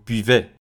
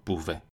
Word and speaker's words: buvez: برای همین من میگم نو بیوان و بیوه بیوه بیوان نه که بووان بووه